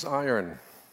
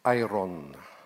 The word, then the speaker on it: iron: The title for today's sermon is Iron Sharpens Iron. When I was a child, I pronounced iron, see, Iron Man. But as I grew up, it's iron.